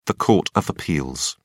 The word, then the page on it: court of appeals: British English